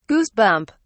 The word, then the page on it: goosebump: British English